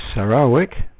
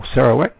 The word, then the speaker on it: Sarawak: So those are a few frequencies of various stations, and I'd like to add that Radio Free Sarawak, or Sarawak, depending on how you'd like to pronounce it,